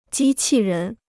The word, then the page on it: 机器人: jī qì rén